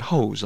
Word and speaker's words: Hoose: We got the Hose Family, spelled H-O-O-S-E, but you pronounce it Hose, like in Roosevelt.